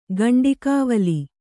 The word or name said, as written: ಗಂಡಿಕಾವಲಿ